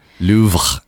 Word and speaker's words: Louvre: Louvre, I think, is how you want to say it.